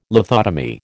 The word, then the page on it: lithotomy: American English